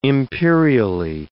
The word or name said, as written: imperially